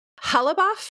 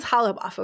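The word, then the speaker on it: Ghalibaf: And I think it's Halabaf is how you pronounce it.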